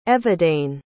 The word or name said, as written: Evidane